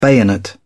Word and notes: bayonet: British English

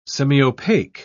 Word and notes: semiopaque: British English

